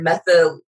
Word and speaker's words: methodical: He was very methodological. How do you say that word? – Methodical. There it is.